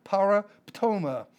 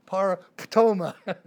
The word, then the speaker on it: paraptoma: P-A-R-A-P-T-O-M-A, long O, paraptoma. It's kind of hard. It's one of those that you almost spit to say it. Paraptoma.